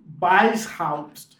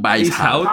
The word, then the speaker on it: Weishaupt: Weishaupt. Adam Weishaupt. – Weishaupt.